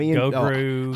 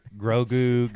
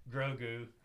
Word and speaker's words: Grogu: We said his name wrong all weekend. Like, Gogru. – I couldn't say it right on the podcast last week. Yeah. Jacob had to keep correcting me. – Gogurt, Grogu, Gogru.